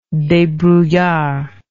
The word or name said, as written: debrouillard